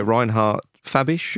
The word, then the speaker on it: Reinhard Fabisch: Their manager, Reinhard Fabisch, am I pronouncing that correctly? Yeah.